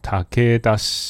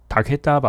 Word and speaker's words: 武田: 那反正就有一个非常呃有名的武将叫做武田信玄，那个发音叫什么？塔克达塔克达吧？武田信玄，塔克达信玄。武田的发音是塔克达，跟竹田一样。塔克达。